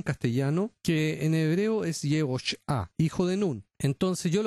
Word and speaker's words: Yehoshua: entonces tendría que leer Yehoshua, porque los masoretas le agregaron una U.